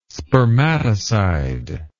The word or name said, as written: spermatocide